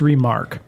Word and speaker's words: remarque: It's just remark. – Remark, but it's spelled R-E-M-A-R-Q-U-E.